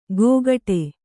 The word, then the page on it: ಗೋಗಟೆ: gōgaṭe